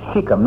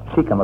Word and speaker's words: Shechem: There's a man down there that she has had some difficulty with by the name of Shechem, not Shechem, as the town is called. Shechem, according to my Bible. A long E there.